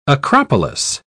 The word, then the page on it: acropolis: British English